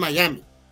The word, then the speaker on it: Miami: El nombre de la ciudad es Miami y así se pronuncia en, en, su ciudad de ellos.